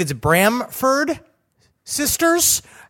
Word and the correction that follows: Bronfman: It's Bronfman. And I'll tell you what it is. It's that F. They stick right in the middle. Bronman? Got it. Bronfman.